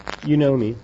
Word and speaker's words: Unomi: Unomi. – Is that how you actually pronounce it? – That is how I pronounce it, yep. – Okay, I know you, but you know me, but, that's spelled U-N-O-M-I for the case of anybody who's looking to find you.